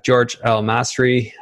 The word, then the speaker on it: Georges El Masri: I have George L. Masri.